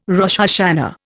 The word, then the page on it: Rosh Hashana: Indian